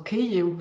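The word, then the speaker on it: Kiew (Київ): Also Kiew wäre auf Ukrainisch die richtige Aussprache. Und Kiew ist eben ins Deutsche als äh, Stadtname eingewandert, weil es natürlich über die imperiale Sprache, nämlich das Russische, äh, auf die Landkarten kam. Und deswegen wurde es dann ins Deutsche als Kiew integriert, aber das ist tatsächlich die russische Aussprache, genauso wie der Fluss Dnieper, ne, also auf Russisch, auf Ukrainisch der Dnipro ist.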